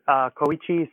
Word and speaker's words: Koichi: It aired November 12th, 1998, written by Judd Lynn, directed by Koichi Sakamoto, and that's how we pronounce it, I'm pretty sure. And to help us talk about this episode, we have another special guest. – Hey! And yeah, that sounded, Koichi sounded great.